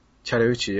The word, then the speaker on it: Chattahoochee: Chattahoochee, yeah.